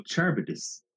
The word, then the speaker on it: Charybdis: No, this is Charlebidis. Charlebidis. No, Charybdis. – It's Charybdis. – It's Charybdis. – Charybdis. There we go.